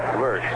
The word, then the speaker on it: Lersch: Name is spelled L-E-R-S-C-H. Lurch, Larry Lurch.